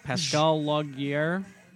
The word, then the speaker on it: Pascal Laugier: Pascal Laugier.